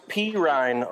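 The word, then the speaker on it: Perine: It's pronounced Pirine over there for Gore. Pirine, I believe. Is that right? – Pirine. Yeah, Pirine. Sam J.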